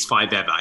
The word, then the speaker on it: Five Ever: It is pronounced Iggy and Ace Five Ever. Is that right? – Yes, like forever, but one more.